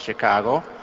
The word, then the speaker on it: Chicago: Once I was listening to him on television talking about the great city, our great city of Chicago, and he said, you don't pronounce it she. Chicago. It's chi, like chick, you know, or like chicken.